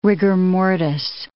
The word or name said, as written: rigor mortis